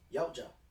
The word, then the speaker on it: Yautja: Got pronounced the T in there. Yautja. – Were they Japanese? – No. Actually, the T is kind of silentish.